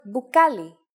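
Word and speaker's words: bukali (μπουκάλι): For this one, Greek uses a combination of mi and pi, like in the word bukali, meaning bottle.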